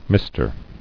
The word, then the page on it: Mister: Mis·ter